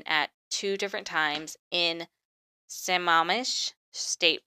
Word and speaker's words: Sammamish: It's Sammamish. No, I think I said that right. Sammamish.